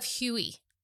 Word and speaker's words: Huet: It's from the House of Huey. Huet. H-U-E-T. Silent T, as they do in France.